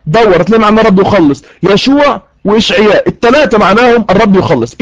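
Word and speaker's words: يشوع (ישוע): النقطة رقم اتنين، النقطة رقم اتنين، قلنا ان يسوع في العبرية تنطق يشوع.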